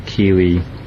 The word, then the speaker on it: kiwi: And so Kiwi, the pronunciation of Kiwi is K-I-W-I, Kiwi. Maori uh-huh. has a similar pronunciation to the Japanese language, A-I-U-E-O. They pronounce the vowels.